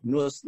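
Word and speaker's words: Nous: N-O-U-S. N-O-U-S. Logic. Noose Logic.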